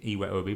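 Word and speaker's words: Iwobi: And then, he took off Iwe Obi. Shout out to the actual pronunciation of his name, Iwe Obi.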